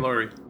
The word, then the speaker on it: Laurie: Is it Laurie? Am I saying that right? – It is Laurie. Laurie Strode.